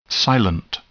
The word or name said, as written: silent